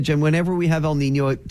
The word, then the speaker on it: El Niño: You have to say it like this, El Niño. – El Niño.